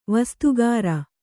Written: ವಸ್ತುಕಾರ

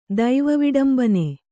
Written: ದೈವವಿಡಂಬನೆ

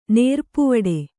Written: ನೇರ್ಪುವಡೆ

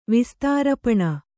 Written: ವಿಸ್ತಾರಪಣ

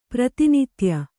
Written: ಪ್ರಾತಿನಿಧ್ಯ

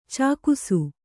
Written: ಚೆಕ್ಕಿಸು

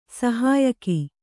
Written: ಸಹಾಯಕ

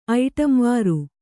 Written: ಐಟಂವಾರು

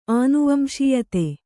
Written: ಅನುವಂಶೀಯತೆ

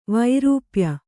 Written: ವೈರೂಪ್ಯ

